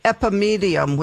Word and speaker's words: Epimedium: One is Epimedium, which is spelled E-P-I-M-E-D-I-U-M.